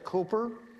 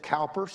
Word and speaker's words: Cowper: An Englishman, they pronounced it Cooper, but we pronounce it Cowper, C-O-W-P-E-R.